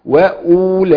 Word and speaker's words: وأولئك: وأولئك. نعم. الواو عندنا مرسومة في أولئك، تمام، لكنها لا تنطق، هي مرسومة فقط، هتلاقي عليها صفر مستدير حضرتك في المصحف كده، طالما عليها صفر مستدير ده لا تنطق.